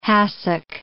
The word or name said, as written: hassock